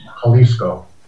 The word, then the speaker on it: Jalisco: The Spanish J is an H, so it's Jalisco.